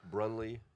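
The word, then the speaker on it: Breunle: Facebook, Instagram, Liam, and then the last name is B-R-E-U-N-L-E, B-R-E-U-N-L-E, Brunley. It's spelled just like it doesn't sound.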